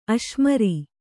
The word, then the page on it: ಅಶ್ಮೀರ: Kannada